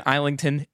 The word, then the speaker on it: Islington: Today we're reviewing The Strength of You, book two of the Hierarchy series by James Islington. I hope I got his name right. – Is it Islington? Islington? It is. It's James.